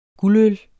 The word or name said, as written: guldøl